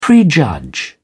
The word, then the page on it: prejudge: British English